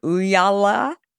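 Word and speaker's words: Ooyala: His first startup was a company, I'm probably not going to pronounce it exactly right, but Uyala. So it's perfect.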